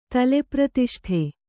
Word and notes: ತಲೆಪ್ರತಿಷ್ಠೆ: tale pratiṣṭhe

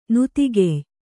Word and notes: ನತಿಗೆಯ್: Kannada

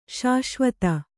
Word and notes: ಶಾಶ್ವತ: śaśvata

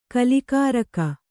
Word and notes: ಕಲಿಕಾರಕ: Kannada

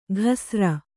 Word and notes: ಘಸ್ರ: ghasra

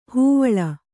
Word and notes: ಹೈವಳ: haivaḷa

